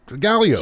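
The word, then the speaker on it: Gallyot: So my real last name is French, G-A-L-L-Y-O-T. T is silent. When I was signed on with the station, my boss at the time said, that's not going to work. – Yeah, I understand. – Yeah, people won't be able to pronounce that. And I said, give people the benefit of the doubt. Like, the T is silent. Just Galio.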